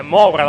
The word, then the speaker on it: Moura: Moura, quello è Moura la pronuncia corretta.